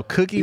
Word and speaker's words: Cookey: And it's spelled different. – It's like, you want a cookie? – So cookie man, C-O-O-K-E-Y, that came from, I came back from Alabama and I went to Alabama State for a year.